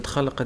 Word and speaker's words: خلق: خلق دي اصعب كلمه هنا عشان اللام بين الخاء والقاف. شوف خلق قلما تجد ان القارئ ينتبه هنا الا الحاذق طبعا، والمتقن يتكلم عليه من يقرا ان هو الكلمه صح نطقن كانت ثلاثه حروف خلق، لكن الترقيق اللام بين الخاء والقاف هذه لا ياتي به الا إيه من اتقن، ودي المطلوبه.